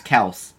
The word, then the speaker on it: Kelce: people would read it and say the E, but the actual name is Kelce.